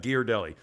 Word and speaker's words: Ghirardelli: Ghirardelli. It's actually spelled G-H, but they pronounce it Ghee.